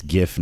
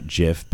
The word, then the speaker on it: GIF: Yeah, I mean, yeah, yeah, yeah, So anyway, um, uh, the, the they they decided that it's GIF, not JIF, because it's a you know a hard G.